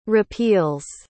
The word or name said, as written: repeals